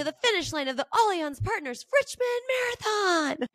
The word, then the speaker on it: Allianz: It because you're so it's spelled Allianz, like A-L-L. Yeah. But yeah. I like Googled it to have them respond like to have the it said to me, and it's Allianz, and it's I think it's French.